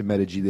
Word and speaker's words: Medig: Yeah, Medical Injury Group, otherwise known as Medig, and it's spelled M-E-D-I-G, for those listeners out there. So it's Medig.